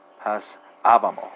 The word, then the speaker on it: passavamo: So it's spelled D-E-P-E-N-S-A-M-E-S, I believe, right? And Italian speakers translate the French into the Italian, and you have passavamo, right? Passavamo, right? So you put an emphasis on that penultimate A.